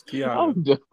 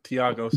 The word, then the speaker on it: Thiago: It's Thiago. The H is silent. – Is it or is it not or is it Thiago? – It's the H is silent. Thiago.